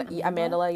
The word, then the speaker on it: Amandla: Like, Zendaya Amandla, is mm-hmm. that how you say her name? – Amandla, yeah. And then Yara? Is that her name? Y-A-R-A.